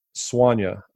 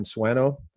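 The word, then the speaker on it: Swanö: is dedicated to the nuttier excursions of one Don Swanya, who I suppose Americans know better and pronounce as Dan Swano or Don Swano. In the interest of keeping it real but not alienating anyone, we'll just refer to him in the Americanized vernacular. Swano.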